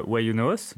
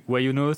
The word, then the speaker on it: YunoHost: Why You Know ça, ça s'écrit Y-U-N-O-H-O-S-T. Et du coup, c'est un jeu de mots qui est pour, euh, pour la phrase anglaise Why you no host, qui veut dire pourquoi est-ce que tu ne t'auto-héberges pas.